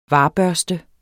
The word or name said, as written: varbørste